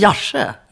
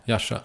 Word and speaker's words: Järvsö: Kan du inte säga Järvsö som det ska uttalas? – Alltså, Järvsö. – Järvsö. – Ja, uppe i Järvsö.